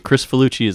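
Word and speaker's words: Kricfalusi: Ren and Stimpy Show, animated series from John Crisfalusi? – Chris Falucci. I just found that out recently. Chris Falucci is how it's pronounced.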